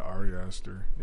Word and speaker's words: Ari Aster: Yeah, Ari Aster.